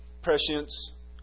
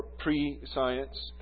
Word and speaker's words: prescience: One is merely prescience or prescience, or prescience would be the way it's pronounced, but prescience, which is knowing the outcome ahead of time.